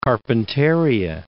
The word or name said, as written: Carpentaria, Gulf of